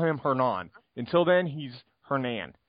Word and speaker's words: Hernan: Hernan. The H is silent. Hernan.